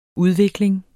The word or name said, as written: udvikling